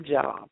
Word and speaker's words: Job: And if you are thinking now, well, I saw a job in the Bible. No, that's a book in the Bible, and that's actually Job. It's spelled like job, but that's Job.